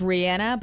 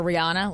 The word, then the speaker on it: Rihanna: Technically, it's Rihanna, but I feel like we all got so used to calling her Rihanna, like whatever.